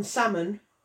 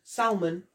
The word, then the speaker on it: salmon: as option one, salmon, which is the proper way to say it, just to let you know, or option two, Zach's version, salmon. And please let us know, because...